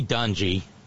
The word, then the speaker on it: Dungy: Tony Dungy, I'm sorry it rhymes with grungy.